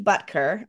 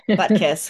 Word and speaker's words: Butker: I keep calling his name wrong. I keep saying Butkus, who is a was a another football coach, was Dick Butkus. So I have the name messed up in my head.